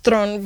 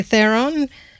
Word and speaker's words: Theron: It's actually pronounced Theron because she's South African. But if you're English, you say Theron.